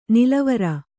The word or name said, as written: ನಲವರಿ